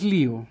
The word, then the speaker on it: Leo: Es Leo, se pronuncia Leo. Se escribe Leo, se pronuncia Leo en inglés.